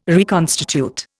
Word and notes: reconstitute: Indian

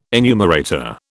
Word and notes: enumerator: British English

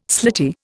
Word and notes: slitty: Indian

